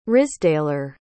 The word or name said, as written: risdaler